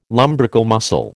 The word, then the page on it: lumbrical muscle: American English